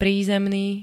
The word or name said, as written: prízemný